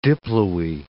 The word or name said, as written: diploe